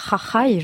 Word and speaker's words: Jajai: Un chant festif, Jajai, je ne sais pas si on prononce bien. – C'est parfait.